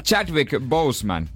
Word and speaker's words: Chadwick Boseman: Niin Chadwick Boseman, joka näyttelee mustaa panteria, Black Panther, Marvelin supersankari.